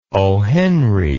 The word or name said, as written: ohenry